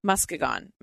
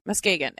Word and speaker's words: Muskegon: who granted Kathy a meeting with the Muskegon. – He's just making this up. – I don't think that's how it's pronounced. – Let's look it up. – Muskegon. Muskegon. It sounds like a dinosaur.